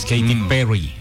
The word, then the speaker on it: Katy Perry: Allá no, la doble R no la pronuncian como acá. El rrr. Es Katy Perry. Ellos no pueden pronunciar bien la R.